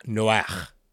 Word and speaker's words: Noach: So in Hebrew, it would be pronounced Noah, Noach.